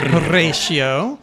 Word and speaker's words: Horatio: He plays Horatio. Which you have to roll your R's. Horatio. Okay. There you go.